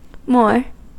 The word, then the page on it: moor: American English